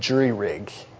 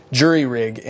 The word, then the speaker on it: jury rig: And there's a word that perhaps you use, it's the word jury rig, and it's pronounced in a couple of different ways, but that's the original way, jury, like a jury, J-U-R-Y, jury rig.